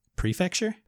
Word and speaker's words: prefecture: How do you say that word? – Prefecture? – Prefecture. Yeah, prefecture. I'm just putting the emphasis on the wrong syllable there, aren't I?